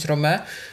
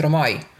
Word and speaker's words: Romae: Więc tu jest na przykład ostatnie słowo Romae, powinno się czytać Rome. Według starej wymowy, według nowej jest Romai.